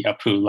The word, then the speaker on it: Apu: You almost said it, but then I tell them all, you can call me Apu, like in The Simpsons.